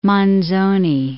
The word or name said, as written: Manzoni